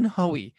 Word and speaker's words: Hoey: I have here with me on the show the one and only Jen Hoey.